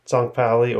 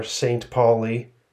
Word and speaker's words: St. Pauli: FC St. Pauli or St. Pauli in the English pronunciation.